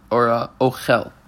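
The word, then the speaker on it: אֹכֶל: L'fikach, therefore, tamo ba'alef, the emphasis is on the first letter, the aleph. – V'nakud b'patach katan, it has a segel, ochel, by under the chaf.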